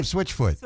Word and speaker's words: Switchfoot: And we're going to end it with a song I was just introduced to in the last year, Switchgood. I mean, Switch. Did I say that right? – Well, swim close. Good. It's Swim Good from Switchfoot.